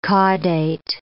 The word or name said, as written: caudate